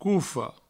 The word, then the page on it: cofa: coufo